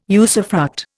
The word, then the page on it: usufruct: Indian